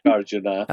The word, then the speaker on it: Nagarjuna: And I'm going to mispronounce it. It's by Nagarjuna. – Nagarjuna.